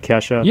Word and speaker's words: Kesha: Am I pronouncing that right, Kesha? – Yeah, of course, of course.